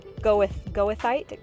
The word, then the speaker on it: goethite: It is said goethite, G-O-E-T-H-I-T-E.